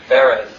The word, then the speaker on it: Baraz: You told me today I've been mispronouncing his last name for the last how many years? Baraz. There I go.